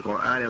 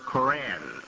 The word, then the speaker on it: Quran: The book of this religion of Islam, al-Islam properly, it's called in the Quran, al-Islam, is the book called the Quran. The Westerners tend to pronounce that word Koran.